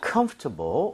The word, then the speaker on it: comfortable: 근데 이거 발음할 때 있잖아요. 원어민들이 comfortable, comfortable 이렇게 부드럽게 하는 사람도 있는데요. – 네. – 오히려 이 단어는요, 이런 발음을 들을 확률이 높아요. 뭐냐면 comfortable, comfortable. 뭐냐면 여러분들 중간에 OR 보이죠? – 네. – 이거를 발음을 거의 안 하는 사람도 엄청 많습니다.